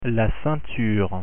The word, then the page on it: ceinture: French